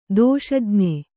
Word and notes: ದೋಷಜ್ಞೆ: dōṣajñe

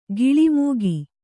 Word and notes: ಗಿಳಿಮೂಗಿ: giḷi mūgi

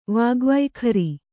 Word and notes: ವಾಗ್ವೈಖರಿ: kannada

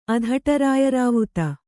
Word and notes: ಅಧಟರಾಯರಾವುತ: kannada

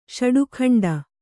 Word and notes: ಷಡುಖಂಡ: ṣaḍu khaṇḍa

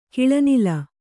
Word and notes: ಕಿಳನಿಲ: kiḷaŋkla

